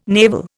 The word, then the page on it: navel: Indian